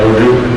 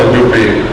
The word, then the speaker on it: Udupi: Udupi. Now it's called Udupi. Udupi. Here it's spelled Udipi, but it's Udupi.